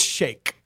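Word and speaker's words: Shayk: I believe it's Shake. Irina Shake. – It's not spelled like that.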